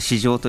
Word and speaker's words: 市場: あのまあ、市場という漢字を経済学ではちょっとかっこつけて市場と読むわけですけど